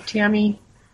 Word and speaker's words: Tami: Tammy Young for Wyoming. And my name is spelled T-A-M-I, and it's F-O-R, not the number. So Tammy, and is T-A-M-I.